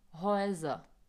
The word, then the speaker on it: Häuser: Zase pozor na výslovnost Haus a U, jakmile Ačko přehlasuju, tak je výslovnost die Häuser.